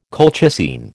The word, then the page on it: colchicine: American English